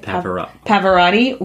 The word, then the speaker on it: Pavarotti: In 1998, when she performed at the Grammys, opera singer Pavarotti, is that how you say it? – Pavarotti was also supposed to perform.